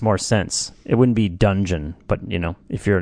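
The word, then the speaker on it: Dungen: If I'm pronouncing that right, Dungeon.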